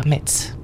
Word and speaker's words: Metz: Vous avouerez quand même que si l'on veut être honnête, on doit prononcer le T et dire Metz.